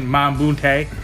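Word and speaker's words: Mbah a Moute: Because they lost Ariza and Mambunte. – Yeah. You said that pretty good. – Yeah. – Yeah. – I've been working on it. – Sometimes you say it wrong, it sounds like my booty.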